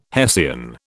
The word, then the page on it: hessian: British English